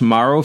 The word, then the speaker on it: Morrow: she's a big Josh Morrow fan does that did I say that right? Or Moro? – Yeah, you did, and, and, I and I know who he is.